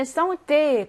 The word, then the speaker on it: santé: 3번에 santé, 건강이라는 뜻이죠.